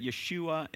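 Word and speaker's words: Yeshua (ישוע): And then did you know that Yeshua is actually how you pronounce Jesus in Hebrew, okay?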